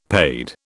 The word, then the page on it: paid: British English